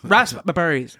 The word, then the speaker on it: raspberry: By emphasizing the fact that raspberry is pronounced with a largely silent P.